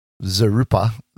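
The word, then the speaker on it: vzeroupper: Zarupa, the V is kind of silent-ish, yep.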